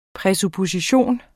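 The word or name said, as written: PRÆSUPPOSITION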